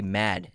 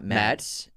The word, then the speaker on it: Mads: I read somewhere, or someone left a comment where you just say Mad, not Mads. Like Mad Mikkelsen. – Oh, no, I'm sorry, it's Denmark. Mad, okay, so you don't say the S? – I think it's pronounced Mad.